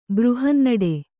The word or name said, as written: ಬೃಹನ್ನಡೆ